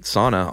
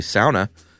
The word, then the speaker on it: sauna: I think I pronounced it sauna all through the episode and got a lot of email that it's actually sauna.